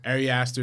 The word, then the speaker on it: Ari Aster: Ari Aster. That's how you pronounce it? – Yeah. – Ari Aster is my number four pick for Hereditary, of course.